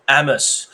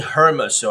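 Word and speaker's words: Hermès: 这是手机的颜色，哦，我真的觉得好棒，好好漂亮哦，OK，so so Amos，我们不要念 Hermes 哦，H 是不发音的，so Amos Orange，爱马仕橘。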